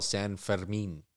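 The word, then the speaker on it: Fermín: Yeah, it's called Feast of, and they call it San Fermin. That's like how they pronounce the guy's name.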